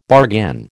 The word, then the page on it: bargain: American English